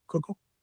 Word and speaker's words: Kugel: There's different dialects of Yiddish, and some people pronounce it Kegel and some people pronounce it Kugel.